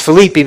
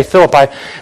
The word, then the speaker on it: Philippi: It's actually pronounced Philippi, but we say Philippi.